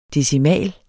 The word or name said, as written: decimal